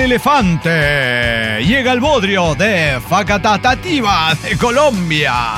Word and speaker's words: Facatativá: Facatativa. Con acento en la última sílaba. – Ah, mire. – Facatativá, Colombia.